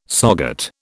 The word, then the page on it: SOGAT: British English